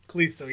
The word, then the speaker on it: Kalisto: They had Apollo Crews, I believe, and Kalisto. Is that how you say his name? – Kalisto, yeah.